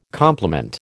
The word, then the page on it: complement: American English